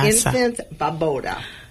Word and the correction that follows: Les Enfants Baobab: Les Enfants Baobab.